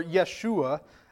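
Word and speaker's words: Yeshua (ישוע): Jesus, or Yeshua, as it would have been pronounced in Hebrew, means the Lord, capital L-O-R-D, Yahweh, is salvation.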